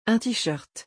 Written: un tee-shirt

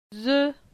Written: Ze

